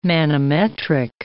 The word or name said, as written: manometric